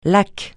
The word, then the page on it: lac: francais